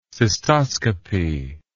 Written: cystoscopy

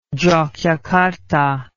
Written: djokjakarta

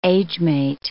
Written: age-mate

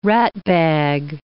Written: ratbag

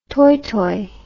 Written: toitoi

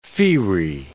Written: feirie

